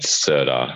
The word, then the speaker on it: Serdar: Actually, I'll start by just saying, how do you pronounce your name so I'm saying it correctly? – It's Serdar Hararovich.